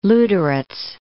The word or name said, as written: Luderitz